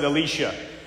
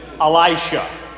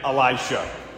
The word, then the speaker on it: Elisha: Many people will pronounce it Elisha. There's Elijah, right? The great prophet. And then the one who succeeds him, Elisha.